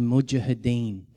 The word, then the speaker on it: Mujahideen: The Mujahideen.